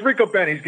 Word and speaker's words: Ricobene's: Rico Beanies. Rico Beanies, fantastic. Ricobene's. What's the proper pronunciation Rico now? – Ricobene's. – Yeah, it, it, it's Rico. It's Ricobene's. Get it right.